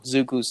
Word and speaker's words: Zuko: Zuko's still my favorite character. – It's Zuko, Zuko. Oh, I always mispronounce his name.